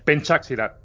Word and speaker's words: Pencak Silat: Pencak Silat.